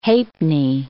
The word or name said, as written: halfpenny